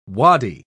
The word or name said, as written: waddy